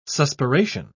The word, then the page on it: suspiration: British English